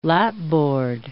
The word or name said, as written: lapboard